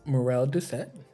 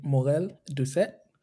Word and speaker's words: Morel Doucet: So my name is Morel Doucet. In English, in Haitian Creole, you can pronounce it Morel Doucet.